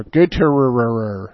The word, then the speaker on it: Gottehrer: G-O-T-T-E-H-R-E-R. Gutierrez.